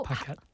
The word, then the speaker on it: Paquette: Yeah, basically it's a French name, but I'm American, so I pronounce it like an American. Paquette.